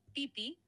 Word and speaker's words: Phi Phi: Seguro te referís a las islas Fifi, que en realidad se pronuncia más como Pipi, sin marcar la F.